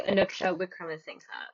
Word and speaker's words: Anuksha Wickramasinghe: Welcome, Anuksha, and I'm so sorry if I mispronounced your name. – Oh, that's all right. My name is actually Anuksha Wickramasinghe.